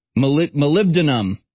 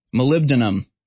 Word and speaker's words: molybdenum: Molybdenum. Molybdenum. That's how it's pronounced.